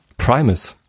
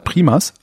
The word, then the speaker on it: Primus: Primus. Wie schreibt man das? Primas? Primus. – Primus. – P-R-I-M-U-S.